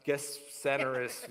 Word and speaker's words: Gennesaret: So they arrived at the other side of the lake in the region of Gennesaret. Yeah. – What was that? – Yeah, that's how you say it in the Greek.